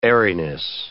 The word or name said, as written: airiness